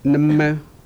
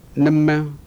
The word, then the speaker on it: Nama: Nama. – Is that, that's how you say it? Could you say it again? – Nama.